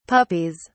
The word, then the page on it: puppies: British English